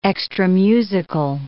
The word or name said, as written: extramusical